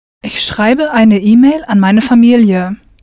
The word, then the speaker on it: schreibe: Richtig, ich schreibe. Pronounce that E ending for the ich person clearly.